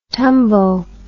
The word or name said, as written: tumbo